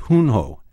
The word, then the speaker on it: Junjo: It's called Hunjo, and it's spelled J-U-N-J-O.